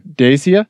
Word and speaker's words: Dacia: the first car you get is a Dacia. Am I saying that right? – Dacia.